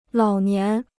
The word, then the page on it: 老年: lǎo nián